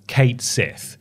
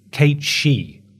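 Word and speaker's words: Cait Sith: That, so there's a few ways to pronounce this character's name. For the longest time, I thought it was Kate Sith. Apparently, that's not right. It's Kate She. If you're pronouncing it the Irish way.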